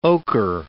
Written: ocher

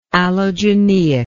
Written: allogeneically

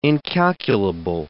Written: incalculable

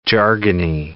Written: jargony

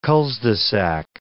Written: culs-de-sac

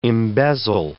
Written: embezzle

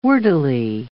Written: wordily

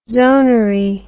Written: zonary

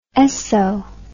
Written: Esso

